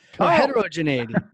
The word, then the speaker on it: heterogeneity: Heterogeneity. – You got it. – Because it's different. All right. Heterogeneity. I'm going to have to go back to the classroom now. All right. Heterogeneity.